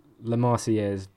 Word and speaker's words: La Marseillaise: Like, Les Marseillais or whatever. It's a bit more upbeat than that, isn't it? – La Marseillaise, but the lyrics aren't.